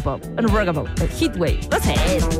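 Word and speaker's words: Heatwave: Yo le decía Hathaway. Y se llama Heathway. Hit de calor, como una ola de calor.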